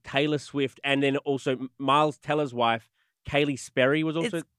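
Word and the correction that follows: Keleigh: I thought that too, but you pronounce it Kelly.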